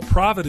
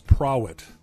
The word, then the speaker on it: Prawitt: Well, Provitt is spelled with a W, too, so everybody thinks it's Prowitt.